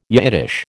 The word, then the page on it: Yiddish: American English